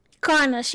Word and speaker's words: Karnas: How do you pronounce it again? Karnas? – Karnas, yeah.